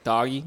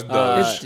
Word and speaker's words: Doge: I wasn't sure if it was like doggy. Like I've heard people say like doggy. – No, it's Doge.